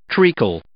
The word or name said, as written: treacle